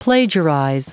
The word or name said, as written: plagiarize